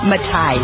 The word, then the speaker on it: Mathai: All right, up next, Richie, is Mathai. Is that how you? – Matai. – Matai, even though it's spelled Mathai.